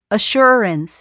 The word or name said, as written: assurance